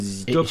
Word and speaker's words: ZZ Top: Parce qu'il faut le prononcer Zizi Top.